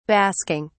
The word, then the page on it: basking: British English